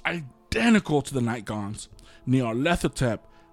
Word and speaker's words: Nyarlathotep: It's Nyarlathotep. – Nyarlathotep, yep.